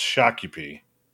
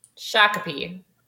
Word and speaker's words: Shakopee: We had absolutely, positively no idea that the town that Valleyfair is in was pronounced Shakopee. – Shakopee.